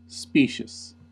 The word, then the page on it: specious: American English